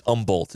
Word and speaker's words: Humboldt: It's Humboldt, isn't it? – It's got an H. Doesn't say the H is silent.